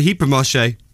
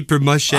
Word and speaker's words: hypermarché: Actually, yeah, they've probably gone to the hypermarché. – Is that how you pronounce it? – Yeah. – Say it again. – To get the wines and all that. – It's called the what? – The hypermarché.